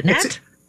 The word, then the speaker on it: Nat: I'm saying Nate, but is it Nat? – It is Nat. – I apologize. – Thanks for having me. – I added the silent E.